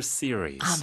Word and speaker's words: series: 시리즈를 영어로 정확한 발음이 어떻게 될까요? – Series. – Series. – Lecture series.